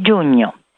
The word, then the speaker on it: giugno: Do you hear this gn sound of giugno? It is the union of GN followed by a vowel and is almost always pronounced gn in Italian. For example, GN plus A is gna. GN plus E is gne. GN plus I is gni, and so on.